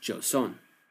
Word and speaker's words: Joseon: Among the territory gained was a large portion of a peninsula to the northeast, whose people were called in China the Chaoxian, and pronounced by the tribal locals as Joseon.